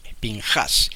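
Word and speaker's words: Pinjás: En el hebreo dice Pinhas o Pinhas. No, algunos judíos dicen Pinjas. Ahora, que porque le cambian el acento. El acento en la Biblia está en la A. Pinjas.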